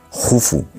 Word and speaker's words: Jufu: Y eso lo aprovecha muy bien su hijo Keops, que por cierto, para cultura general, se escribe Keops, pero se pronuncia Jufu, y casi nadie llama Jufu, porque Heródoto nos lo tradujo del griego y bueno, pues ahí se ha quedado, ¿no?